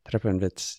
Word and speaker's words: Treppenwitz: Treppenwitz.